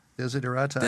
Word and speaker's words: Desiderata: Did I even pronounce it right? Desiderata? – Desiderata.